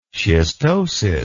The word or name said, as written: schistosis